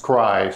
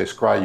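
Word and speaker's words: scri: And that's denoted with a script I, and it's pronounced scry. So that's an old standard in the literature. So that's something that distinguishes someone who's in from someone who's out. If you say scry, you're in.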